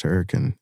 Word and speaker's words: Hurricane: I grew up in a beautiful town tucked amongst the hills called Hurricane, West Virginia, but it's pronounced Hurricane.